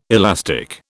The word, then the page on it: elastic: British English